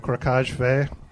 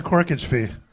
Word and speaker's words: corkage fee: First time I went to a wine bar, I tried to order a bottle of the Crocage Fay. – What's wrong with that? – Yeah, what is that? – How is it regularly pronounced? – That's the corkage fee.